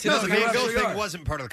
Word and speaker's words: Van Gogh: Not Van Gogh, but Van Gogh. – Yeah, the American pronunciation is Van Gogh. – Uh-huh. – But if you're Dutch or French, you can say it with a How does he say it? – Van Gogh.